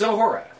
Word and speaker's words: Uhura: It's Uhura. You say it really fast. It doesn't sound offensive.